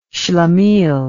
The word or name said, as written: shlemiel